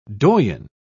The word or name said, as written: doyen